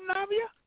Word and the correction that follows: Nivea: It's Nivea, like the lotion, Nivea.